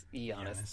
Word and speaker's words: Giannis: Giannis? Or Giannis. Giannis. The G is silent.